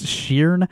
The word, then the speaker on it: Shearn: I think Sheeran is how you pronounce that name. Is this someone you've ever heard of? – Is he related to Ed Sheeran? – No, it's Sheeran. – Oh, okay. – S-H-E-A-R-N is the first name.